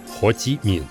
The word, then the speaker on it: Хо Ши Мин: Хашимин правильно произносится Хоти Мин, также известен как Лицуй – также известен как товарищ Вионг, также известен как Тхаутин.